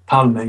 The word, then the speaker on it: Palme: Palme, yeah.